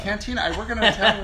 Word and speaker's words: cantina: I think it was Cantina. – It's Cantina. – Is it? – Yeah. I work in an Italian restaurant. We have a Cantina.